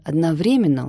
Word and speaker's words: одновременно: Я совсем недавно узнала, что в слове «одновременно» ударение ставится на 3-й слог, хотя у меня 2 высших образования и при этом 2 красных диплома.